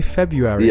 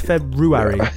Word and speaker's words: February: There's a weird R in it that no one pronounces. Have you ever noticed that? People say February. – Yeah. – But it's February.